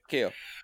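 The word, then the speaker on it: Keough: It's K-E-O-U-G-H. I know. I just know that. – Keo. – But I can have, Keo. Is it? Okay. – Keo.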